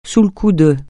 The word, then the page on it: sous le coup de: francais